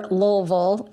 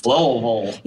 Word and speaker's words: Louisville: He was born July 18th, 1937 in Louisville, Kentucky, or Lowellville, as my fellow Southerners have informed me it is pronounced. – Lowellville.